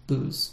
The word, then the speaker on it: booze: Interestingly, it was originally spelled B-O-U-S-E, and sometimes B-O-W-Z-E, but still pronounced booze.